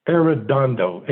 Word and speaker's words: Arredondo: Well, first off, the name is Arredondo, A-R-R-E-D-O-N-D-O.